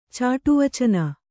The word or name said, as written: ಚಾಟುವಚನ